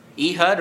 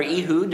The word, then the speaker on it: Ehud: Ehud or Ehud, depending on how you like to pronounce it. E-H-U-D.